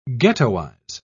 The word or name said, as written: ghettoize